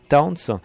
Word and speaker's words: Townes: Alors Towns, ça s'écrit T o e hein, c'est Townes. Hein. Towns.